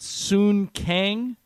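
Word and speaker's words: Sung Kang: Including, I believe the guy's name is pronounced Soon Kang.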